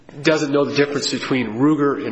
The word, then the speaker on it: Ruger: As an initial fact, it's strange that Mr. Zermiller, who's accused of mispronouncing Ruger as Rugger, is being investigator suspected of stealing this gun, yet he knew the exact title of the gun.